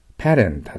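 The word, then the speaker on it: pattern: パターン発音します。日本語ではパターンと言います。だけど発音に気をつけてください。パターンです。パターンで T はラに聞こえるかもしれません。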